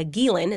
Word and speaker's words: Ghislaine: You filed a motion to intervene and a motion to unseal documents that were related to Virginia Roberts and the Ghislaine, or the Ghislaine is how you actually say her name, defamation suit.